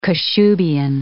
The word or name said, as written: Kashubian